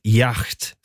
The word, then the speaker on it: yacht: Så när han fick reda sen på att hon skulle komma med sin rika familj i deras yacht, säger man yacht? Du som är bra på tyska uttal. – Yacht. – Yacht, säger man då, ja.